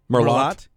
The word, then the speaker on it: Merlot: Don't pronounce the T in Merlot.